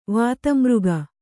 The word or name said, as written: ವಾತಮೃಗ